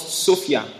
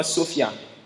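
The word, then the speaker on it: Sophia (σοφία): le mot sagesse ici est un mot grec que l'on prononce Sophia. Est-ce qu'on peut dire avec moi Sophia? – Sophia.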